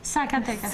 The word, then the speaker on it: Zacatecas: Che tu la Z la pronunci come la nostra S. – Zacatecas. – Zacatecas. – Infatti Zacatecas. Zero, zero. Con la S.